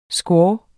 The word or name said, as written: squaw